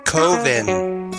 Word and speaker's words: Coven: It's pronounced Coven. Coven sounds like oven, man. Jesus told me so.